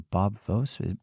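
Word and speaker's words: Voas: One that jumped out at me was Robert Vose, or Bob Vose. Maybe I'm not pronouncing that right. – You are pronouncing it right.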